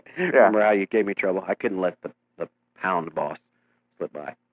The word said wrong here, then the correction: Pond Boss: It's not pound. – It's pond. I know. – Yeah. Quit saying it wrong.